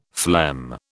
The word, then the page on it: phlegm: British English